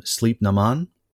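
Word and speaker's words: Sliabh na mBan: In Irish, it's pronounced something like Sleep Naman.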